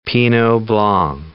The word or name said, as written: pinot blanc